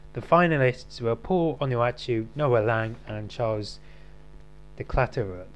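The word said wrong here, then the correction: De Ketelaere: of two players of club. I've butchered that name. – The Catalera.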